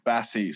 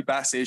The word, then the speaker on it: Bassey: I'm going to go Shirley Bassett's from... Bassey, sorry. I can't even say her name. Shirley Bassey.